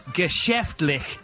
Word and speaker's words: geschäftlich: How do you pronounce that? – Wir sind auf geschäftlich hier.